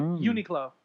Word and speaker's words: Uniqlo: Uniqlo.